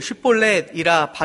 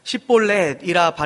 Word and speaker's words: 쉿볼렛: 쉿볼렛이라 발음하라 하여 에브라임 사람이 그렇게 바로 말하지 못하고 십볼렛이라 발음하면 길르앗 사람이 곧 그를 잡아서 요단강 나루턱에서 죽였더라.